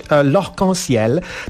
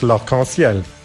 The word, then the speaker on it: L'Arc-en-Ciel: Po francusku powinno to brzmieć L'Arc-en-Ciel, no ale podejrzewam, że Japończycy no, nie bardzo chcą tak wymawiać, więc jak sądzę, nieco skrótowo, ten zespół w Japonii zwany jest Laruku zamiast L'Arc-en-Ciel.